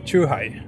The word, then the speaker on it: chuhai: Hur uttalas det? det är, är det Shuhai? Chuhai. Chuhai, mm.